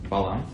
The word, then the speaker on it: Balam: Looks like it's written Bilam, which is very strange, but it's supposed to be pronounced Balam.